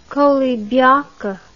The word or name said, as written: koulibiac